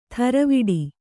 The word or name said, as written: ಥರವಿಡಿ